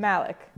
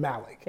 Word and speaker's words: Malik: How do you say your last Malik. – Name? – Malik. – Yeah. Of the Inspection Boys. Brigitte, introduce yourself. – Hi, everybody. My name is Brigitte Malik.